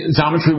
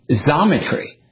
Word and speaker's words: Xometry: Xometry." So he was pronouncing it like a Z, and we had been saying it like Xometry.